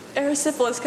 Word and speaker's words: Erysipelas: Erysipelas.